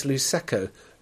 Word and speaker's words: Luceco: Right, Luceco. I've been pronouncing this Luceco, as in Prosecco, I suppose. But it's apparently it's Luceco, L-U-C-E.